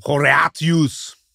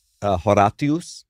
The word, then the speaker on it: Horatius: And how would we pronounce this in classical Latin? – Horatius. – Horatius, yeah.